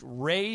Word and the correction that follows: Rhea: Seahorn? – Rhea Seahorn. – Rhea. – Yeah, Rhea.